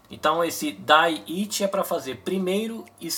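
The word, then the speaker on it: 第一: 第1 Dai Ichi.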